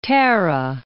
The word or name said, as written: Tara